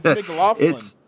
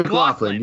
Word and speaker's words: McLaughlin: It's McLaughlin. It's McLaughlin. McLaughlin. You were so close, so close. No R's in there, though.